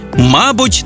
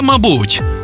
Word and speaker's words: мабуть: 5 українських слів із подвійним наголосом. Байдуже, байдуже, завжди та завжди, мабуть та мабуть, також і також, помилка і помилка.